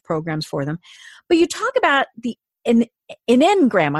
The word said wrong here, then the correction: enneagram: I'm not going to say that right. And I've been that's enneagram. – One of that's it. You say it again. – Enneagram. – It's got too many E's in the wrong places.